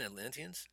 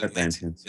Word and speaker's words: Atlanteans: The Wakandans and I can never say it right. Antillians? – Isn't it Atlanteans? – Atlanteans? Is it Atlanteans? Atlanteans. Yeah.